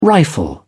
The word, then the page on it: rifle: British English